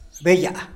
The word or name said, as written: beyaa